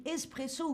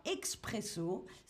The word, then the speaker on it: espresso: Évidemment, comme en Italie, on a l'espresso et qu'on prononce avec une faute, en français toujours, on dit espresso ou parfois expresso, ce qui n'est pas du tout le bon mot, mais euh, c'est euh, la, façon euh, dont on le prononce en France. Un expresso, bien sûr, pas du tout faire l'accent italien, c'est important, un expresso avec un bon, vrai accent français.